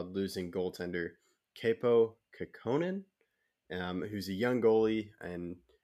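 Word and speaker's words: Kaapo Kähkönen: I also selected Kaapo Kekkonen, and that's how you say his name, Kaapo Kekkonen, yeah.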